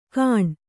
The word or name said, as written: ಕಾಣ್